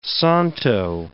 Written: santo

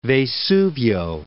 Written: Vesuvio

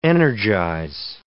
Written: energize

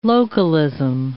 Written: localism

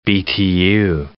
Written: Btu

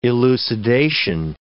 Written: elucidation

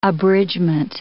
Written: abridgement

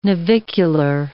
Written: navicular

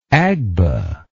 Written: agba

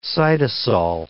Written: cytosol

